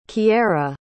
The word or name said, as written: kyara